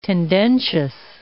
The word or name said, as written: tendentious